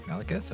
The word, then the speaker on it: Malekith: Malekith? – Malekith, okay.